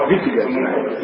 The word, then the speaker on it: Wittgenstein: E per approcciarla uso una citazione di Wittgenstein, non so Wittgenstein, Wittgenstein. – Si pronuncia così.